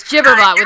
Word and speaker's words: Gibberbot: Oh, it's Gibberbot with a soft G.